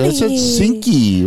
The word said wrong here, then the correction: Cincy: What's up, Cinky? – Cincy. – Cincy, sorry. Shenzhen, China.